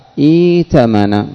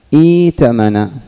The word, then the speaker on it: ائتمن: إذن ائتمن ائتمن الهمزة الثانية ماذا ستبدل؟ ماذا؟ ما هو الحرف؟ حرف المد الذي يجانس الكسرة الياء. إذن ستنطقها ائتمن ائتمن، وكذلك ائتمر ائتزر ائتلق ائتدم ايتم ايتلف ايتوني.